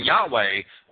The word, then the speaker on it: Yahweh: So we have to have a common language that we communicate in, and identity, Christian identity adherents have been writing Yahweh, Y-A-H-W-E-H, which is basically the way it's pronounced for a long time,